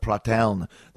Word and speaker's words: Pratteln: I thought it was Pratteln, but it's Pratteln. Finally figured out how to pronounce that. My friend Cesaro, who's from Switzerland, told me how to pronounce that, Pratteln.